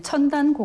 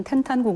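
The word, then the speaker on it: 텐탄 (天坛): 네, 이 첨단 공원이라고 하면은 뭐 우리가 한자어로 그대로 읽으면 첨단이고 현지 발음은 텐탄 공원이라고도 하는데 명나라와 청나라 시대 500년 동안 황제가 하늘에 제사를 지낸 그런 역사적인 명소이죠.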